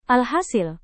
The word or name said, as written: alhasil